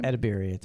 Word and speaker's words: Edebiri: Edebiri.